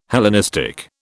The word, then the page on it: Hellenistic: British English